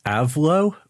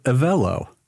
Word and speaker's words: Avlo: It looks like it should be pronounced Avlo, but they actually pronounce it Avello.